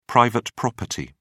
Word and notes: private property: British English

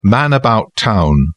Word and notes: man-about-town: British English

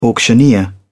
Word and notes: auctioneer: British English

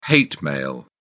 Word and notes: hate mail: British English

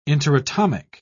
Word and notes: interatomic: British English

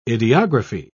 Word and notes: ideography: British English

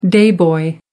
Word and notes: day boy: American English

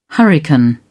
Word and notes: hurricane: British English

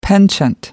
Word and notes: penchant: American English

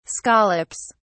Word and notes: scallops: British English

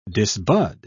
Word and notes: disbud: British English

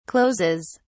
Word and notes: closes: British English

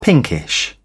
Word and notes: pinkish: British English

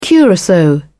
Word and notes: curacao: British English

